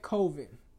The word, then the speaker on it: Colvin: So today, we have Claudette Colvin. Clavin. Pronounce that. – Colvin.